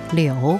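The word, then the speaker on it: liu (柳): Meanwhile, an easier and perhaps more modern interpretation is that, in Mandarin, the pronunciation of the willow tree, liu, sounds very similar to the character liu, which means asking someone to stay.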